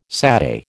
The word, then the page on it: satay: American English